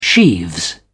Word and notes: sheaves: British English

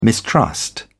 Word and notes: mistrust: British English

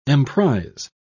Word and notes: emprise: British English

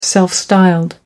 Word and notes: self-styled: American English

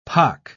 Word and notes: pock: British English

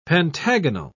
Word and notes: pentagonal: British English

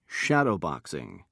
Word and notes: shadowboxing: British English

